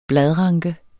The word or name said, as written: bladranke